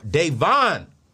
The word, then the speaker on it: Davonne: So y'all, let's get into it, because I got my girl, Davon.